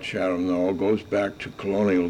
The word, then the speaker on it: Chatham: When I first came to Chatham in the early days, my boyhood days, Chatham was not pronounced that way. It was always called Chatham, like Wareham, like Eastham.